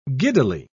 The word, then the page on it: giddily: British English